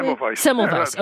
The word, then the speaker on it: Semmelweis: So Semmelweis, did I say his name correctly? – Semmelweis. – Yeah, okay.